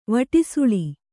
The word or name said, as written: ವಟೃಸುಳಿ